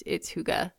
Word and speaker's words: hygge: It's actually spelled Hygge, and there's a good chance I'm butchering the word. – Okay. – But I'm pretty sure it's Hygge. – But Hygge sounds fun.